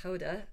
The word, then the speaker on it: Gouda: Middelburg and Gouda.